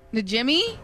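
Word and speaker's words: Najimy: Najimmy? – Yeah. – Is that how you pronounce it? – N-A-J-I-M-I.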